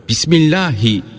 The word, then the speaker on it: Bismillahi: Sonra Bismillahi Allahu Ekber diyerek deveden başka hayvanın boğazının herhangi bir yerinden kesilir. Bismillahi derken H'yi belli etmek lazımdır. Bunun için her zaman Allah Teala dememeli, Allahü Teala deyip H harfini belli etmeye çalışmalıdır.